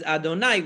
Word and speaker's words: Adonai: We always pronounce it as Adonai.